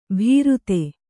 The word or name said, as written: ಭೀರುತೆ